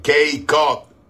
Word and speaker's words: Cape Cod: Desde Cape Cod. Gloria al Señor, Massachusetts. Cape Cod.